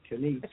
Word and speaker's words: Denese: My name is Denise. – Can you spell that, please? – Oh, Denise, D-E-N-E-S-E. – Oh, Denise. Sorry, it didn't come through. – Yeah, sound like Denise.